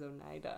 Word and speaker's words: Oneida: I went there and I talked to them and they told me that it was Oneida, actually.